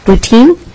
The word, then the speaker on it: Boutin: So next we have Kathy, is it Boutine? Please come forward and put yourself on the record. – Mm-hmm. I'm Kathy Boutin. My husband's fault for the French pronunciation of the name.